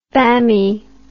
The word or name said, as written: bammie